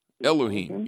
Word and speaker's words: Elohim: Elohim, yeah.